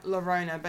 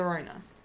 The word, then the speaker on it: Llorona: It's spelt with two L's, so it looks like Llorona, but it's like, you know how you say guillotine. – Oh, yeah. – Llorona. La Llorona.